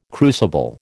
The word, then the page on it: crucible: American English